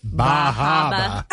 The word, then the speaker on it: Bar Harbor: Only if you're going to say it, you have to say it correctly. It's the Bahaba. The Bahaba Lobster Bake.